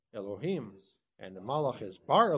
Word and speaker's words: Elohim: Well, the pasuk always says Elohim, but when it's referring to God, we pronounce it Elohim.